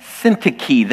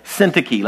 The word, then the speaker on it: Syntyche: And Syntyche. The emphasis is on the first syllable. Syntyche. Let me hear it. Syntyche. Yeah, there you go. It's not Syntyche or Syntyche. It's Syntyche.